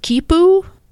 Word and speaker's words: quipu: And also, he's learned how to use the, I think it's pronounced Kipu? It's mm-hmm. Q-U-I-P-U.